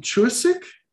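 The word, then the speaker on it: Chuseok (추석): And it's pronounced Chuseok.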